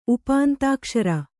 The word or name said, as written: ಉಪಾಂತಾಕ್ಷರ